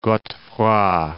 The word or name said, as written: Godefroy de Bouillon